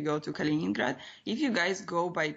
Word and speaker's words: Kaliningrad: Kaliningrad, yes, Yes.